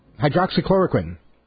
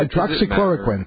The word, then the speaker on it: hydroxychloroquine: hydroxychloroquine. I'll be fine, bro. Did I say it right this time? – Yeah, that was close enough. – Okay, good. Yeah, hydroxychloroquine.